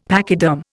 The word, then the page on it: pachyderm: Indian